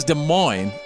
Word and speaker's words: Des Moines: As I take you out of Des Moines, and it is Des Moines, Iowa.